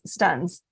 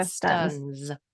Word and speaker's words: Stunz: Oh, I'm not picky, but it's Stunz. Andrea Stunz.